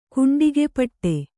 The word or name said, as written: ಕುಂಡಿಗೆಪಟ್ಟೆ